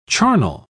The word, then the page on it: charnel: British English